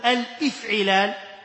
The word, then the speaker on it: الافعلال: الباب الثالث افعل، افعل نقول يفعل افعلالا، وهذا اسمه باب الافعلال، لا تقل الافعلال، الافعلال بهمزة وصل، باب الافعلال.